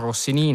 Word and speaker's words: Rossini: E poi abbiamo di nuovo il tema iniziale, addirittura il pappagallo che saluta Rossini, dice bonjour Rossini, naturalmente con l'accento finale sulla I alla francese, eh, bonjour farceur, no?